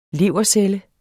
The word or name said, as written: levercelle